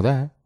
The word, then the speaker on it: the: Aquí recuerda que la palabra da tiene dos pronunciaciones. Se pronuncia como da cuando la siguiente palabra empieza por una consonante. Y se pronuncia como di cuando la siguiente palabra empieza por una vocal.